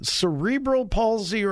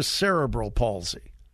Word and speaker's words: cerebral palsy: Now, and by the way, the correct pronunciation, cerebral palsy or cerebral palsy? – People, that's interchangeable, but most people say cerebral palsy.